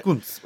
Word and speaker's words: Cuntz: It's spelled C-U-N-T-Z or Z, depending on your country. – Kunz. – Maybe it is Kunz. – Kunz. – Kunz. – Kunz. – Yeah, that's probably right. – Kunz.